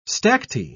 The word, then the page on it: stacte: British English